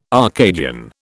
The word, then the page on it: Arcadian: British English